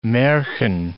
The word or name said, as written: marchen